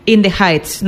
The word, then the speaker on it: In the Heights: Tu primera recomendación es In the Heights. No sé si lo he pronunciado bien. Nada. – Perfectamente.